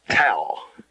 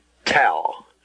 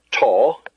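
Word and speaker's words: tau (ταῦ): The next letter is tau. Tau. Not tall. Tau. Like the word cow. Cow.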